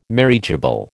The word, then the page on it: marriageable: American English